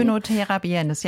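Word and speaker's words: Oenothera biennis: Onotera biennis, ja.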